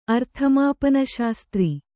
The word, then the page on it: ಅರ್ಥಮಾಪನಶಾಸ್ತ್ರಿ: arthamāpanaśastri; kannada